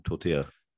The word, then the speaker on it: tortilla: Tortilla, the correct way of saying tortilla. – Tortilla, tor- tortilla. I think it, I mean the double A, the double L in in Spanish gets a lot of different pronunciations. When you go to Argentina uh, okay. – Uruguay, it's actually a sh sound.